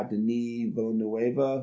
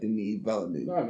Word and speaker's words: Denis Villeneuve: This one is directed by Denis Villeneuve, who people know from Sicario.